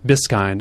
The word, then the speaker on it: Biskind: I'll pronounce it like David Susskind, Biskind.